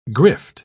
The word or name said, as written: grift